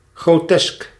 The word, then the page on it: grotesque: /ɡɹəʊˈtɛsk/; /ɡɹoʊˈtɛsk/